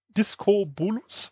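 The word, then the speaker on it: Diskobolos: Also Diskus ist ja eine wichtige olympische äh, Sportart und da gibt's ja die ganzen äh, Statuen auch von dem klassischen Diabolocus, wie spricht man das aus? Disco Bolus.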